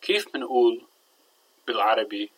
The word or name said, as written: keef min2ul